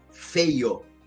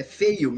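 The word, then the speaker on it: Feyo: Quer dizer, meu sobrenome, pessoal, é Feio. F-E-Y-O. É feio mesmo, se pronuncia feio e tal.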